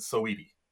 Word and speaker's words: Saweetie: And I looked it up just to make sure I pronounced it right, and you do pronounce it Saweetie.